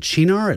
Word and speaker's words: Cynar: We pronounce it Cynar. It's C-Y-N-A-R.